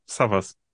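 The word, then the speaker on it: Savvas: Das ist türkisch. Also ich bin halb Grieche. Also mein Vater war Grieche. Und im Türkischen wird es Savaş ausgesprochen. Also da wird es aber nur mit einem V geschrieben. Mein Name wird mit Doppel-V geschrieben, von hinten wie von vorne. Savaş.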